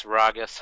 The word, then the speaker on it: Ragas: You know, it doesn't really matter how you say it because it's kind of bastardized Spanish, so Ragas, however you want to say it.